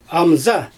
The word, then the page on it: amza: Xyzyl